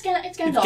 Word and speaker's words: Gandalf: It's not a silent L? – Gandalf. No, it's Gandalf.